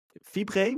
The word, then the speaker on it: Fibre: Jij bent president of Fibre, moet ik het zo uitspreken? Ja, klopt.